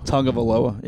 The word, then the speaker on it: Tagovailoa: Tagovailoa. Yeah, there you that go. Is correct. Tagovailoa. There's no N in the name, in the pronunciation, like in the spelling, so it kind of I have a hard time with that one for whatever reason.